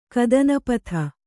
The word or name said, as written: ಕದನಪಥ